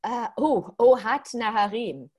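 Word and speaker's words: Ohad Naharin: Ohad Naharim, Gaga lecture and demonstration with him.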